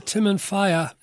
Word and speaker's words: Timanfaya: Well, tell us about the Timanfaya, if that's the way to pronounce it, National Park. – Yeah, you're spot on.